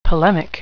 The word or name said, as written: polemic